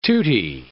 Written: tutti

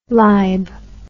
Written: libe

